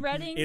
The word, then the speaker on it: Reading: And I realized that in Monopoly, I have been saying Reading Railroad wrong the entire time. – It's pronounced Reading. – It's pronounced Reading.